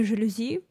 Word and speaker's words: жалюзи: И вот тут уже, кстати, может проскользнуть в мыслях русского человека, что словечко-то заморское, но там еще и ударение, да, на последний слог, непривычное для русских. Поэтому, кстати, многие произносят его у нас неверно, как жалюзи.